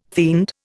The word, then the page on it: fiend: Indian